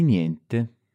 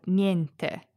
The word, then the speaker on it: niente: Notice the pronunciation here of niente. In some ways, it's similar to the ending of grazie. – Grazie. – Which we talked about in the last episode. We've got this yeah sound at the end. But this time, it has an N before it.